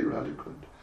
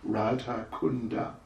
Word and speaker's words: Radha Kunda: I could just make a tiny, minute little grammatical comment that, you know, in Sanskrit, Radha Kund, we say Radha Kund, in Sanskrit it is Radha Kunda. That's actually the correct pronunciation. That's the spelling, which is phonetic, and that's the pronunciation.